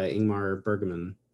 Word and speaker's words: Ingmar Bergman: Persona is like an early uh, Ingmar Bergman film. I probably just mispronounced his name, and I feel terrible. – Um, I don't think you did actually.